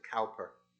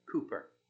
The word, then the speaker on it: Cowper: You might pronounce it Cowper. Cooper is how it's properly pronounced.